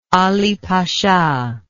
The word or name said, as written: Ali Pasha